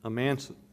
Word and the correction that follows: amanuensis: his secretary, amanuensis, sorry, I can never pronounce that word.